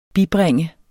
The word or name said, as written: bibringe